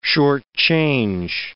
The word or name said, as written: shortchange